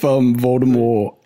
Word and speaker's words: Voldemort: I wonder if Voldemort is actually how she originally intended to pronounce it, or how she just decided to retcon it just to mess with everyone. She's like, no, it was always a silent T.